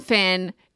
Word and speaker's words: Fionn: The O is silent. It's Finn. That's what they say in the audiobook.